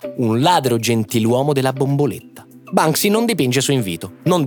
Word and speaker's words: Banksy: Il nome è chiaro, Banksy. – Anche se noi in Italia non so perché lo pronunciamo spesso come Bansky.